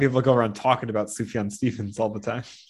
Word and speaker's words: Sufjan: and then like Sufjan Stevens Chicago. – Sufjan, just a soft J. – Is it? It's like just so I'm joking, but like Yaz flute, but like it actually is Sufjan, yeah.